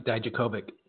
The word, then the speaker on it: Dijakovic: Just to have a ladder match with Dojokovic. Did I say his name right? – Dominic Dijakovic.